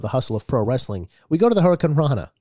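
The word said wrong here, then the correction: Hurricanrana: No, you said it wrong. Here, listen to him. He says it right. – Another spectacular finisher is the Hurricane Rana. Slither tries to whip... – The Hurricarana. Hurricarana.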